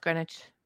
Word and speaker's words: Greenwich: It's Greenwich. – It says Greenwich. – It's pronounced Greenwich. – Oh, so we're both right.